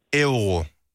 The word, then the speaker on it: euro: Euro. – Altså ev som i ærgerligt ro.